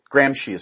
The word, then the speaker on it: Gramsci: I think you pronounce it Gramsci. – Gramsci is actually correct.